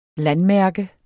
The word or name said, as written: landmærke